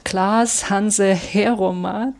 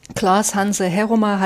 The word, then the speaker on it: Klaas Hanzen Heeroma: Dahinter verbirgt sich der Dichter und Sprachwissenschaftler, jetzt kommt wieder ein niederländischer Name, Klaas Hanse Heroma, denke ich, so spricht man den aus. Der hat von 1909 bis 1972 gelebt. Klaas Hanse Heroma hat als Sprachwissenschaftler gewirkt.